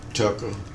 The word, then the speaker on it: Tucker: My name is Earl Tucker Nicholson. E-Earl, E-A-R-L, Tucker, T-U-C-K-E-R, Nicholson, N-I-C-H-O-L-S-O-N.